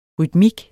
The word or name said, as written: rytmik